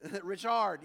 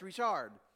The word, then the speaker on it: Richard: Richard, yeah, because he was French. Yeah, he would have been mad if I mispronounced it. But he was French, Richard.